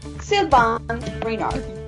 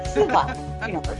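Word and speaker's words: Sylvain Reynard: Sylvain Renard. Y de hecho, estuve averiguando y en francés sí se dice así. Así que es Sylvain Renard.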